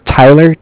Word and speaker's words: Tyler: Okay, my name is Tyler, T-Y-L-E-R, mm-hmm. and I'm with Bank of Cardiff. It's spelled C-A-R-D-I-F-F, as in Frank Frank.